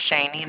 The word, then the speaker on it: Shaney: My name is Shani, and it's spelled S-H-A-N-E-Y.